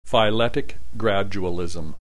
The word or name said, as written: phyletic gradualism